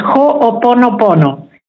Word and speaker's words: Ho'oponopono: Am I pronouncing it almost right? – Almost, almost. Ho'oponopono.